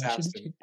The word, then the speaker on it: Astin: John, I find myself saying Austin every time. Is it Astin? Is it Austin? How do I pronounce Austin? – It's Astin, actually.